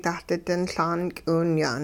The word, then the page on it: dahdi-din Laan k'iwinya'n: hupa